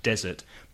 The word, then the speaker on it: desert: Desert, desierto, has the stress on the first syllable and one S, desert. But in a restaurant, you ask for dessert with the stress on the second syllable, dessert.